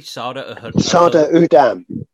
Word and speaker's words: Sardar Udham: Sada Udam. Sada is like a Sikh prefix. It means that you're a Sikh. Udam is the name of the guy.